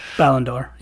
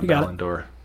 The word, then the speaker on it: Ballon d'Or: I never know how to say it. – Ballon d'Or. – Yep, yeah, Ballon d'Or. I think I was over confusing it here.